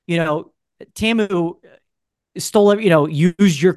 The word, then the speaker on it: Temu: Oh, Temu.